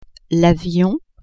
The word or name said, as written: avion